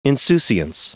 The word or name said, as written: insouciance